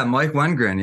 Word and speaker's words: Wengren: Yeah, Mike Wengren, yeah.